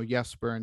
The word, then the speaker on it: Jesper: And that's what other players have done, because you're right, where they're from, it would be, you know, Jesper and Johnson and stuff. But the American hard J, when they come to the USA, they do sometimes, some players choose to use the American pronunciation.